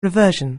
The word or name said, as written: reversion